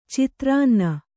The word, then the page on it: ಚಿತ್ರಾನ್ನ: citrānna